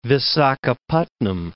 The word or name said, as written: Visakhapatnam